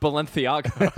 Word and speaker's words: Balenciaga: It's actually pronounced Balenciaga.